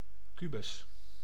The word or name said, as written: cube